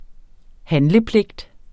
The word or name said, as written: handlepligt